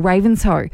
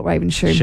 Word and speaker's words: Ravenshoe: I wonder if also the shoe, Ravenshoe, would also be, some people call it Ravenshoe because it looks like it's spelled Ravenshoe. – Shoe, yeah.